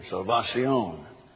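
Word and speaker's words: salvación: get the French out of the Spanish, mi salvion, salvacion, salvacion. Accent on the end, salvacion.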